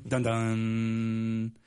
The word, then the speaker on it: Dundon: This Tom Dundon guy, though, his nickname could be Netflix because of the way you could say his name. Dundon. Because Netflix, the sound that Netflix.